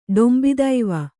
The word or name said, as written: ಡಂಭತ್ವ